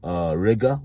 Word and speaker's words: Riga: A lot of these Indians in, like, not Lviv, well, some in Lviv, but mainly in, like, fucking Riga, which rhymes with nigga.